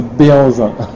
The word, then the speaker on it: Behanzin: The French version is Behanzin.